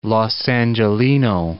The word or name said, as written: Los Angeleno